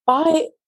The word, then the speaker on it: Bide: The other thing that we tested, and this isn't food, something a bit different, and I hope I'm pronouncing this right, bead boxes? – Bide? I think. BIDE.